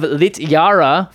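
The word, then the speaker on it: Littjara: And then Reflections of Lit-Yara from Paldheim. Yeah. This card is real good in this deck. – Good pronunciation as well.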